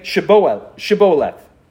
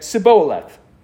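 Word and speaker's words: Shibboleth: And when he said, 'No,' they said to him, 'Then say Shiboleth.' And they said, 'Siboleth,' because he could not pronounce it right.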